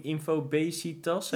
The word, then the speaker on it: infobesitas: Vanwege de in infobesitas? – Eh, ja, zeg ik het goed nu? precies dat.